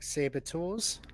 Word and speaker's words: saboteurs: Well, that you were some kind of sabotages. – Like saboteurs? – It's the one. That's how you say it.